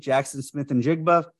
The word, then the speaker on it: Jaxon Smith-Njigba: The Bears at nine are taking, again, Jaxon Smith-Njigba, wide receiver, Ohio State.